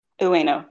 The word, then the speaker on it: Ueno: Ueno, yeah.